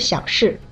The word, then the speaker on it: 小事: 哦，小猫消失了，不是小事。 – 啊，对对，消失对吗？ – 嗯，这回对了，你刚才发音发成小事了。小事的小是三声，事是四声，意思是很小的一件事，不重要的事，所以放在这句话里就不合适了，对吧？